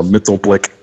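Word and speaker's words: Mxyzptlk: I know, Mittelblick. – Now, see, that's the Super Friends pronunciation. I think that's the most common way that people still say that.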